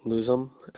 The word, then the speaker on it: Luzum: Chad Luzum, Luzum. – Spell that last name again for me. – Luzum.